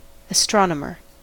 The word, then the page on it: astronomer: American English